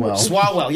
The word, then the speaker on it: Swalwell: Do you think it's a great night for somebody like Eric Swalwell, who I think I don't even say his name right. – We know but his name. Swalwell, yeah.